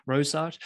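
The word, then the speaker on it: Rorschach: How you pronounce it? Rosash.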